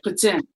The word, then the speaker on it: poutine: Your pronunciation of poutine was that a French-Canadian pronunciation? – That would be a Sudbury, and that would be the only correct pronunciation. – Okay. – Poutine.